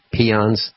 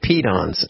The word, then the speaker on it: peons: I think you said peons. – We're on the radio. Peed-on. – I pronounce it peed-ons. I guess I never saw it spelled properly. Okay.